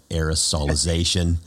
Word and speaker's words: aerosolization: Or is this I don't even know how to say it, aerosolization? – Very good.